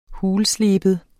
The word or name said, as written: HULSLEBET